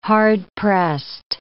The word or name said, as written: hard-pressed